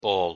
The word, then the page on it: ball: English